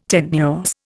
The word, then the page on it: tenuous: Indian